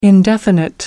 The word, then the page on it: indefinite: American English